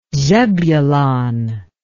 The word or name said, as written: zebulon